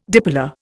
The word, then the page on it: dipolar: Indian